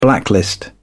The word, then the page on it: blacklist: British English